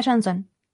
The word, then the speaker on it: Johnson: Sí, Dakota Johnson.